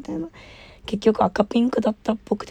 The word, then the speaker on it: 赤×ピンク: えー、赤ピンクはですね、あの赤かけるピンクって書いて赤ピンクって読むみたいなんですけど、調べた。